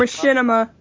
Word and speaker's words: Machinima: I am going to partner myself up with how do you say this? Machinima? Or is it I don't even know. I think it's Machinima. – Machinima. – I knew it was that. Okay. My freaking friend was kept saying it. That's not how you say it. But I was like, what? That's how it's freaking spelled.